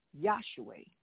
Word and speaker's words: Yahshua: The origin of Jesus' name is found in the Old Testament, means Joshua, Joshua, and Joshua, pronounced Yahshua or Yahshua.